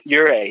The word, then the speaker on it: Ouray: Ouray, yeah.